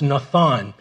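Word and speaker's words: natan: And that word given in the original language in the Hebrew is pronounced nathan.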